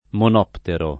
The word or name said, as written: monoptero